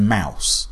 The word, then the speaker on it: Maus: because I've never read Mouse, which is spelled M-A-U-S.